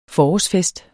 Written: forårsfest

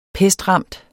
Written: pestramt

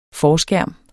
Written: forskærm

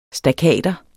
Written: stakater

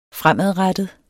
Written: fremadrettet

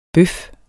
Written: bøf

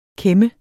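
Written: KÆM

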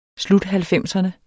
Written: sluthalvfemserne

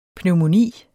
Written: pneumoni

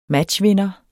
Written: matchvinder

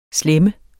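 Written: slæm

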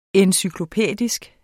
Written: encyklopædisk

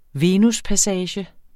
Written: VENUSPASSAGER

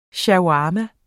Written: shawarma

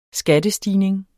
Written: skattestigning

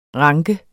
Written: ranke

